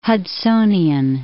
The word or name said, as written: Hudsonian